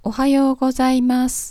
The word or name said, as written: ohayou gozaimasu